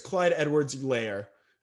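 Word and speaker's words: Clyde Edwards-Helaire: Well, I'm pretty sure it's Clyde Edwards Hilaire.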